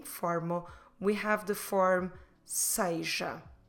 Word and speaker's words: seja: S-E-J-A. Seja. And I know that when I'm pronouncing it, it doesn't look like S-E-J-A, because I'm saying seja. This is one possible pronunciation closer to what it is, the standard pronunciation. If you hear people saying seja, that's just as good. But don't forget, you can say seja, but you should write it S-E-J-A.